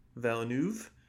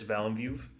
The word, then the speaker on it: Villeneuve: Villeneuve. – And Eric Roth, directed by Denis Villeneuve.